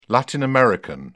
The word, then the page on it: Latin American: British English